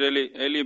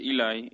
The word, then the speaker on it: Eli: Sí, eh, se tiende a decir Eli, pero es Eli, Eli Roth. – Eli.